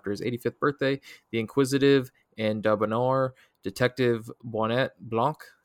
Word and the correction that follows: Benoit: How do you say his name? – Benoit. – Georgie. Benoit Blanc. – Benoit Blanc.